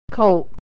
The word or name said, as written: colt